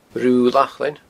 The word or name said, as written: Rubha Lachlainn